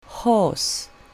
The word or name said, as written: horse